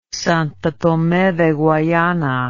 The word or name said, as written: Santo Tome de Guayana